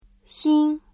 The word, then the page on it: 新: xīn